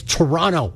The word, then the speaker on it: Toronto: A lot of people get, like, really upset and offended if you say Toronto and pronounce both T's. My girlfriend's originally from the city, and she got grumpy with me a few times. She's like, it's Toronto. Nobody says the second T.